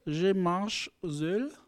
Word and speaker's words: Je marche seul: Je marche seul.